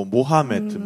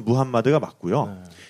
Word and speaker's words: 무함마드: 우리 그냥 보통 이제 무함마드 또는 뭐 모하메트 뭐 또는 네. 마호메트 이렇게 부르는데 네네 정식 발음은 무함마드가 맞고요.